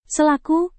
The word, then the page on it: selaku: Indonesian